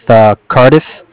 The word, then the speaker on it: Cardiff: Well, my name is Brian with Cardiff.